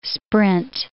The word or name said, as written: sprint